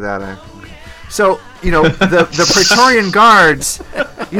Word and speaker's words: Praetorian: In fact, remember we talked about maybe the Praetorian Guards, am I saying that right? – Praetorian.